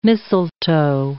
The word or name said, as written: mistletoe